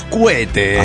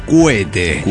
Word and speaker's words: cohete: Mis queridos desintegrados, aquí la Puma de Juárez, escuchando la historia del chavo que perdió un pedacito de dedo por los cohetes. – Ah, sí que sí. – Porque no se dice cohete, se dice cuete.